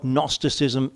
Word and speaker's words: Gnosticism: If you just think of the phonetics, and it's spelled G-N, but the phonetics Gnosticism, think knowing. Those who followed and taught what we call Gnosticism, oh, it's knowing.